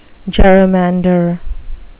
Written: gerrymander

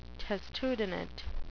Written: testudinate

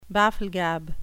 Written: bafflegab

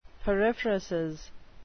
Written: periphrasis

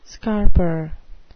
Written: scarper

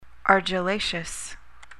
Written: argillaceous

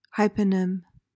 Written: hyponym

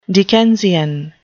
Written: Dickensian

